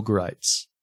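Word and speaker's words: Gritz: Greitz. And it, by the way, it rhymes with whites.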